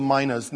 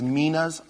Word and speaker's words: minas: I want to get into the parable of the minas. Now, some people say that the pronunciation is minas. I'm okay, but I'll call them minas.